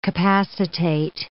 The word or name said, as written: capacitate